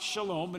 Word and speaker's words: shalom: So in the Old Testament, the word for peace was called shalom. Okay? It's pronounced shalom, and it means peace.